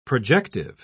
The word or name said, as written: projective